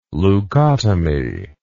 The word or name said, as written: leucotomy